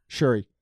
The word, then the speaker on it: Shuri: Shuri.